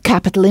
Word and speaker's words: capital: It's of capital importance. – It's of capital importance, oui. En insistant bien sur la première syllabe, ka, capital. C'est comme si vous frappiez sur cette première syllabe, capital.